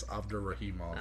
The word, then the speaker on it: Abdurakhimov: He does have some losses to Fabricio Werdum, unanimous decision, got KO'd by Derek Lewis, got TKO'd by Shamil Abdurakhimov. – It was close. Actually, you did a good job there. I don't think you pronounced the K. I think it's Abdurakhimov.